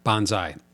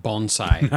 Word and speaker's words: Banzai: Banzai. Not bonsai. Not bon- it doesn't deal with plants.